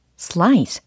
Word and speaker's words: slice: 슬라이스 땡 슬라이스 딩동댕 네 슬라이스 슬라이스 슬라이스 이렇게 S와 L이라는 자음들이 조합이 되어 있으니까 슬라이스, slice, sl, sl, sl 이렇게 발음 처리를 해주셔야 되겠습니다.